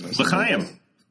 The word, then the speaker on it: L'chaim: L'chaim.